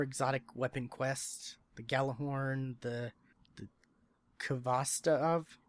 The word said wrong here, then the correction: Khvostov: it's Khvostov.